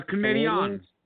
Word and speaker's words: Canadiens: Le Canadiens. Le Canadiens. – Le Canadiens. See, you even talk like a retard. – That's the French pronunciation. They are a French team.